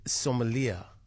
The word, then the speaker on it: sommelier: In the meantime, I'm going to introduce you to South Africa's very first certified bread sommelier. Did I pronounce it right? – Yeah, you did.